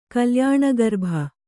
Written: ಕಲ್ಯಾಣಗರ್ಭ

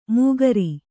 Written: ಮುಖಾರಿ

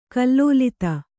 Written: ಕಲ್ಲೋಲಿತ

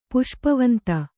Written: ಪುಷ್ಪವಂತ

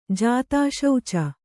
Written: ಜಾತಾಶೌಚ

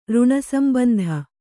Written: ಋಣಸಂಬಂಧ

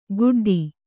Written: ಗುಡ್ಡಿ

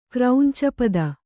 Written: ಕ್ರೌಂಚಪದ

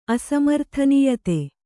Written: ಅಸಮರ್ಥನೀಯತೆ

